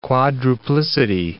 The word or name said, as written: quadruplicity